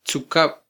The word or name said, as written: tzukab